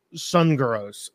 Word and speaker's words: Sunergos: And then as well as Sun Gurus. So I'm going to be honest. I've never heard of the coffee shop. It's spelled S-U-N-E-R-G-O-S, Sun Gurus.